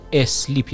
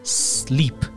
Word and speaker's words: sleep: نکته بعدی درباره تلفظش هست خیلی ها رو شنیدم که میگن اسلیپ یعنی اولش رو یه ا میذارن میگن اسلیپ در حالی که این کاملا غلطه و اولش ا نداره یعنی با صدای سه شروع میشه sleep اینجوری با تلفظ کنید sleep I'm sleeping ببینید sleeping نمیگیم ما I'm sleeping یعنی با صدای سه همیشه باید شروع